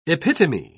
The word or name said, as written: epitome